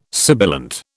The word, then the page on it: sibilant: British English